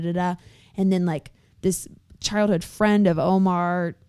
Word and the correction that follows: Omer: I might be saying it wrong. It's Omer, is saying, oh, well, you know, he always talked about like Michael Jackson's very fond of him.